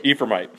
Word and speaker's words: Ephraimite: How do you say that? – Ephraimite.